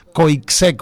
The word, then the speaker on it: Coixet: Entonces, es una muy buena directora, repito el nombre, Coixet, se escribe Coixet con X, Coixet, pero ella es catalana.